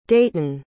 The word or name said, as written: dayton